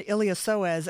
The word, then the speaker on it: iliopsoas: And you remember my golden retriever, Chip, who had a torn iliopsoas, and I'm really proud of myself for actually saying that correctly.